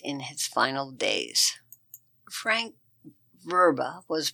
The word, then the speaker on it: Vrba: Francis Allen Ruba, it's spelled V-R-B-A, of Marengo. Francis Allen Ruba, known as Frank, 81, of Marengo, Iowa, passed away May 6, 2023, at Compass Memorial Healthcare in Marengo.